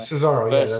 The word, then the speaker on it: Cesaro: Is it Cesaro? If I'm pronouncing it wrong, just ignore me. – Yeah, Cesaro, Rusev and Nakamura versus Ricochet and Aleister Black.